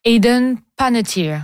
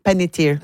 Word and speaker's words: Hayden Panettiere: Mais malheureusement, on souligne son décès au très jeune âge de 36 ans du côté de Hayden Panettiere. – Hayden Panettiere. – Ah, c'est ça. J'ai fait une inversion de syllabe. Panettiere.